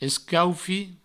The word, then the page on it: escaufit: escaufi; provençal